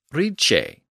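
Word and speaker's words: rīce: So in Old English, we have the word R-I-C-E, which meant great or wealthy. But it was not pronounced rice like modern English. Instead, the CH sound had emerged in this context, and the word was pronounced ricce.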